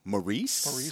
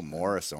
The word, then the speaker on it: Maurice: Maurice Orange City. – No, Morris Orange City. Yeah, talk like a native.